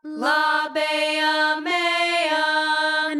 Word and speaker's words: Labia mea: Okay, ladies, that's close, but this is Diversionary Theater, so we've got to get our pronunciation right. Repeat after me. Labia mia. – Labia mia. – Labia mia. – Labia mea. – And now this time just Francis. Labia mea.